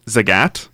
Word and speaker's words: Zagat: So, it's pronounced Zagat. – Zagat. – Yeah. Think of it as rhyming with the cat or the hat. Yeah, it's the last name of the founders, Tim and Nina Zagat. So, there is a reason it's pronounced that way, because that's how their last name is pronounced.